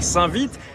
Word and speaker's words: Saint-Vite: Et on est avec une habitante de Saint-Vite, je me suis renseigné sur la prononciation, on dit bien Saint-Vite, comme l'allure du peloton.